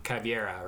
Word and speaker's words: Caveira: It's Valkyrie and Caveira, right? – Caveira, depending on how you say it.